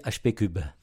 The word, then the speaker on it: HP3: Alors HP3, c'est puissance 3, donc il faut prononcer HP cube.